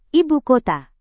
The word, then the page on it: ibukota: Indonesian